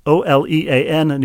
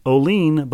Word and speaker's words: Olean: As someone originally from that area, now living in Dayton, I feel compelled to let you know it isn't pronounced Olean, but Olean.